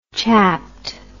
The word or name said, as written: chapped